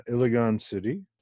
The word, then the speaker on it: Iligan City: To begin with, you were born in Iligan City. Am I pronouncing that right? – Yes. Yes.